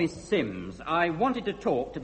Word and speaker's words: Simms: It's spelt S-I-M-M-S, but pronounced Sims.